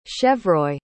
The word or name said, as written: chevreuil